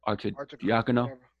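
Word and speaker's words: Arcidiacono: Archer Dini. Archer Diakono. – Yeah, there you go, right there. I knew it sounded right.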